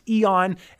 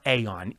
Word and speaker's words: Eon: I'm still having trouble remembering that this is pronounced Eon and not Aeon. Eon, not Aeon.